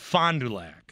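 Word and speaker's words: Fond du Lac: The Pride of Fonjolac besides you. – Yeah, it's pronounced Fondulac.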